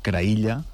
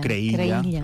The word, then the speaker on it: Creïlla: No hi cauré ara, No, que és Creïlla. – Creïlla. – O Creïlla, pronunciada a la valenciana.